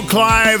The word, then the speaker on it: Clive: I'm Michael Clive. That's C-L-I-V-E. This is Pat Dardis with a D-A-R-D-I-S.